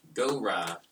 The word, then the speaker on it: Gora: Gora. Is that what I'm saying that right? – That is correct. – Actually, I think there's more emphasis on the go, but.